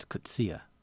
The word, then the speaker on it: Coetzee: Do I say it right? No one ever knows, do they? – Actually, he came here, and it's Coetzee. – Coetzee. – Yeah, I thought there were three syllables, you know, you look it up online, it gives you one phonetic spelling.